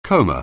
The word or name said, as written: coma